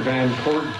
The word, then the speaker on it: Van Court: My name is Steve Baker, B-A-K-E-R, Commissioner of the Board of Prison Terms. – My name is Arthur Van Court, V-A-N, capital C-O-U-R-T, Commissioner of Board of Prison Terms.